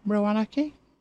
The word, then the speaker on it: Roanoke: It's R-O-A... Hang on. R-O-A-N-O-K-E. Roanoke? Roanoke?